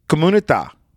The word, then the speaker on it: comunità: So it's spelled C-O-M-U-N-I-T-A. And the I has a little accent over it. So comunità. Now we say community. Community. Community. There's two M's. There's one M in Italian.